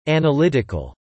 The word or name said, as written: analytical